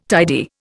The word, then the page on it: tidy: Indian